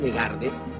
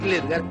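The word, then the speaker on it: Legard: Me dicen Legarde, pero no sé lo que es. Insisto que es Ledgar y sale al revés. Es Ledgar, es fácil, es palabra llana. Me lo pronuncian como le da la gana. Se dice Ledgar y no Ledgarde, pero yo sé que nunca lo voy a lograr.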